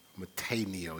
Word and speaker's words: metanoeo: Now, it's not necessarily, when you watch it online, it's not necessarily spelled that way, but trust me, it's metaneo, even though it's spelled M-E-T-A-N-O-E-O, but it's metaneo, so that meta, that first part of that means after, and neo, of course, means change.